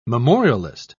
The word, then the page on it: memorialist: British English